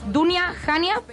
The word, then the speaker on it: Dunya Hanya: Entonces, pues bueno, vamos a hablar de, ese proyecto cultural de Dunia, Dunia Jania Patrimonio. ¿Lo he dicho bien? Estupendamente.